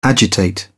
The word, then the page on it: agitate: British English